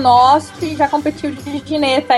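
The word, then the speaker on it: Ginetta: Como que se pronuncia corretamente? Gineta, Gineta, como que é? – Gineta, o correto é Gineta.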